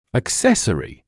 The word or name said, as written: accessory